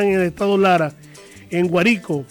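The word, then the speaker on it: Guárico: De las siete parroquias que tiene el municipio de Trinidad Morán, una de las parroquias que lleva por nombre Guarico, se pronuncia Guarico, y estamos en sintonía.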